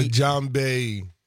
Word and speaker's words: djembe: DJ. – The djembe.